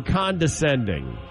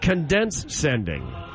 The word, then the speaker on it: condescending: My wife says I'm condescending. However, she pronounces it condense-sending.